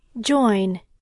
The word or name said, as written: join